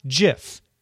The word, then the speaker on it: GIF: It is a soft G, pronounced GIF. End of story."